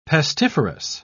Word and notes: pestiferous: British English

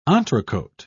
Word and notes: entrecote: British English

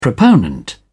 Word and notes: proponent: British English

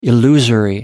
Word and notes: illusory: American English

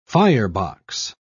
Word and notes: firebox: British English

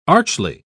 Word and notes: archly: British English

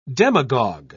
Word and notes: demagog: British English

